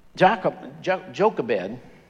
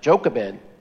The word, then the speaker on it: Jochebed: Jochebed, I wouldn't have pronounced it that way, but that's how they say to pronounce it. Jochebed herself was God-fearing, was trying to hide this tiny baby.